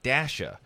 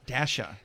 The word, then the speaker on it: Dasha: So the backstory here is that we heard a couple days ago, Mr. Travis Creeley, the midday guy here at 98Q, mispronounced the name of an artist as I did when she was new. Her name is Dasha. – Dasha.